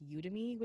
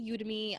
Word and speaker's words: Udemy: Udemy, although I just learned that it's actually pronounced Udemy, which is My friend told me that. – Yeah, so it's actually Udemy.